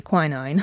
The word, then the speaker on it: quinine: And it had something in it called quinine. I think Americans say quinine.